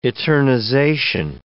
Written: eternization

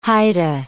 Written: Haida

